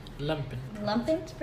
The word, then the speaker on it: lumpen: So it's like there's Patrick as like correct me if I'm saying this wrong, the limpet proletariat. – Lumpen proletariat, and then SpongeBob as the proletariat, and then Sandy as the bourgeoisie.